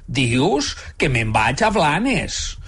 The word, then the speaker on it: Blanes: En català central pronunciem Blanes, igual com fem amb el plural de la plana. Una plana, dues planes. Només hi ha dues situacions concretes en les quals té una certa lògica lingüística pronunciar Blanes. En primer lloc, si hables en castellano, pues Blanes, como Barcelona, Blanes.